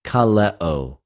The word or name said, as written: καλεω